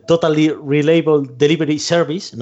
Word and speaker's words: Totally Reliable Delivery Service: Y, hay un juego que llegó a, Game Pass, que es el Totally Reliable Delivery Service.